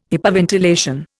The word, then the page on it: hypoventilation: Indian